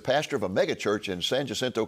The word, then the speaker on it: San Jacinto: Great pronunciation on the name, by the way. – Jacinto. Thanks to your coaching. – Yeah, San Jacinto.